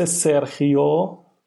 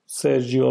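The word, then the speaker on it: Sergio: جی مثل سرخیو سرجیو راموس خا خخی یعنی هر جی ای خا خونده میشه جی ای خ خونده میشه جی آی خی خونده میشه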